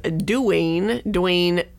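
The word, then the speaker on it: Duwayne: D-U-W-A-Y-N-E. I know, because I was like, because I sat there, I was like, Dwayne. And I looked twice. I was like, what? – Is this how they all spell it? – Yeah, I know. So the director is Dwayne Dunham.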